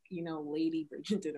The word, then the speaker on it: Bridgerton: You watch Bergington, right? – Yes, yes, yes. – I don't know if I'm saying it right, y'all. – Oh my God. Yes, that guy, Lady Bridgerton. – Bridgerton.